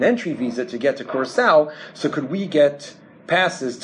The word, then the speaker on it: Curaçao: So the opportunity arose that there was a Dutch colony in the Caribbean called, and I believe this is the correct pronunciation, Curaçao, mm-hmm. Right? Curaçao, is that? – Yeah.